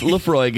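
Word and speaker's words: Laphroaig: Is that how you say it? – Yep. Lafroig. – Okay. – Nope. No. No. No, no, no. – Oh, no! – No. It smells like... – Lafroig, pronounced Lafroig, is an all-malt Scotch whiskey from the remote island of Islay in the Western Isles of Scotland.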